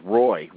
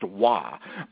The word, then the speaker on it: Roy: You see a guy's name on the back of his jersey, it says Roy. Well, no, it's pronounced Wah.